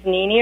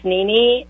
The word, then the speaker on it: Nini: My name is Nini Urschel. I'll spell that for you. It's Nini, N as in Nancy, I, N as in Nancy, I.